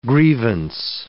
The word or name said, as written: grievance